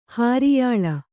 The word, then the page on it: ಹುರಿಯಳಿ: huriyaḷi